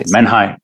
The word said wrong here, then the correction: menhir: Oh, what is the pronunciation? It's Menhir.